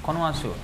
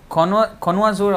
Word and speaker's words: connoisseur: The exact word is connoisseur. Con- connoisseur. Is that the, is that how it's pronounced? C O N N O I S S E U R. Connoisseur. It's actually a French word.